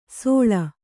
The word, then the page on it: ಸೋಳ: kannada